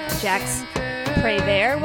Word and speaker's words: Prévert: So this is, this song was based off of a poem by a French poet, Jacques Prévert, we'll say, is how you pronounce it.